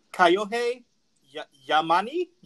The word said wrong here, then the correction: Kyohei Yamane: No, go ahead, Jake, take it. – Hold on, I'm looking, oh, Kyohei Yamane.